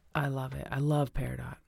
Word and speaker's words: peridot: I don't know if I'm saying this right. Peridot? – Yeah, peridot. – Or peridot.